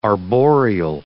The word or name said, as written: arboreal